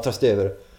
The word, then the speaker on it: Trastevere: Jag stod på balkongen på hotellet i Trastevere uttalas det va? – Ja, Trastevere.